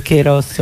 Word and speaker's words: Kairos: A parte la pronuncia sarebbe Kairos, ma insomma vabbè.